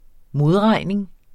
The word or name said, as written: modregning